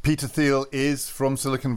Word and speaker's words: Thiel: There was a very interesting speech on Monday by a man that we've decided should be pronounced Peter Thiel.